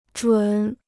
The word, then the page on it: 准: zhǔn